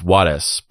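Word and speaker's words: Juárez: And if you were to pronounce it the correct way, it's Juarez, but I'm just going to say it the white way. But yes, technically it's Blas Juarez, but I sound ridiculous saying that.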